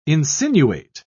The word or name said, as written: insinuate